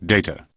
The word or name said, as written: Data